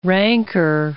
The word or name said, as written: rancor